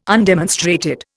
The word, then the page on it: undemonstrated: Indian